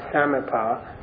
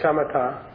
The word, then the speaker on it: shamatha: Some people leave out the H here because there used to be a diacritic there and they left it out when someone printed it and they call it samatha. Samatha. This is the correct pronunciation. So we're going to say shamatha.